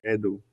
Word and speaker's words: Edu: With the Spanish accent, you say Edu. It's like a ooh.